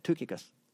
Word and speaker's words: Tychicus: I looked it up. That's how you say it. Tychicus. The lexicon's right anyway.